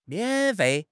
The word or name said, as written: mian3 fei4